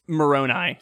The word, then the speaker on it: Moroni: And it's Moroni. Sorry, I mispronounced it.